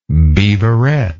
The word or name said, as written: beaverette